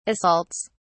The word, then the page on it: assaults: British English